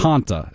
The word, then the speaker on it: Hanta: Hanta. It's the Hanta. You know, like the soda, Fanta.